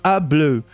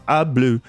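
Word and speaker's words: Y Blew: É, quando eu falo A, é sempre o artigo, né? O A ou O, que a gente pronuncia A, mas é o Y. Então é muito comum nomes de banda galesa começarem com Y. É, na verdade, fala A Bleu, é, os cabelos nesse caso tal.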